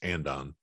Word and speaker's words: andon: And I don't, real quick, do they call andon or ondon? What's the pronunciation? I always in English in the United States, it's andon.